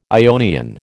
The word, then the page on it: Ionian: American English